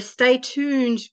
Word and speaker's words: Stay Tooned: Her Stay Tooned group of companies, did I pronounce that right? – You did.